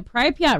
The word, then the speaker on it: Pripyat: He credits his freakish athletic ability to having grown up swimming in the Pripyat River adjacent to the location of the Chernobyl disaster.